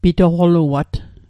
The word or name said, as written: pitoqoluat